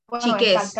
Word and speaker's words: Chiques: Chiques, porque tiene acento en la E.